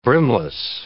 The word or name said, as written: brimless